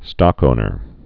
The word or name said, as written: stockowners